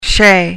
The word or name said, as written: chez